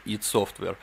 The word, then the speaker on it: id Software: Кто еще раз в комментариях напишет, что id Software правильно читается не id, а ID, будет умирать в мучениях. Идите в Википедию и читайте транскрипцию. id Software произносится как id Software.